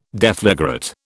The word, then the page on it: deflagrate: British English